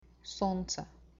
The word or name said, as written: solnce